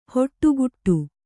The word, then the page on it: ಹೊಟ್ಟುಕುಟ್ಟು: hoṭṭukuṭṭu